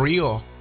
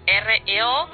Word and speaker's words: REO: Pero no es así Rio, que, no se pronuncia como Rio. – No, es R.E.O. letra por letra. – O sea que sería R.A.O. – Claro, R.E.O. letra por letra, no se pronuncia Rio.